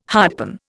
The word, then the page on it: hardpan: Indian